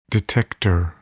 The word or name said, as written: detector